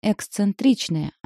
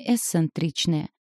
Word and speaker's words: эксцентричная: Поэтому я лишь сказала, что правильно говорить «эксцентричная», а не «эсцентричная», на что она кивнула, пробурчала что-то и вернулась к нарезке зеленого лука.